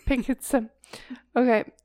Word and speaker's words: Picts: Yes, to pronounce all the letters. picts. Okay, so one day he saw them burying one of their own near the riverbed of the River Ness.